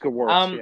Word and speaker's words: Gewirtz: And like Brian Gewirtz aside, am I even saying that right? I think it's Gewirtz or Gewirtz. – I think you're right. – I think it's Gewirtz. – It's Gewirtz, yeah.